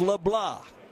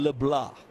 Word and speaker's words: LeBlanc: I said LeBlanc, it's LeBlanc. Got to quit saying it. It's spelled just like LeBlanc, but pronounced Tyson LeBlanc. The French pronunciation.